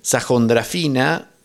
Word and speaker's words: Sahondrafinda: Sajondrafina, eso que yo pronuncio como J, es una H, Sajondrafina o Sajondrafina. El Iragassi o Giragassi es un tipo de música tradicional, ¿tá?